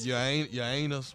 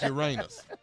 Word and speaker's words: Uranus: Your anus. Where's your anus at? – Uranus. – Uranus. – Uranus. – Oh, that's how you say it? – Yeah. Yes, crazy.